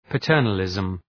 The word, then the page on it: paternalism: pə’tɜ:rnəlızm